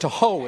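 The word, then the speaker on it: tuho (תֹהוּ): And the word for formless in the Hebrew is tuho. Tuho. It's one Hebrew word. Tuho is how you pronounce it.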